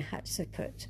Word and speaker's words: Hatshepsut: And then from there, we went to the terraced temple of Queen Hatshepsut. We were told the way to remember how to say her name is hot chicken soup. So it's hot soup.